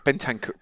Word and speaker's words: Bentancur: Do you know how to say his name? – It's been... do you know what? How's it spelled? – It is Bentancur. – Bentancur, isn't it? Bentancur.